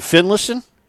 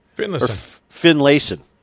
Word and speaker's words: Finlayson: And Finlayson? – Finlayson. Finlayson. Yeah, you would say it faster. Finlayson.